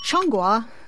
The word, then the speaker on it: Zhongguo: It's Changhua, which is my terrible tonal pronunciation of the name for China, which... tends to be spelled Z-H-O-N-G-G-U-O.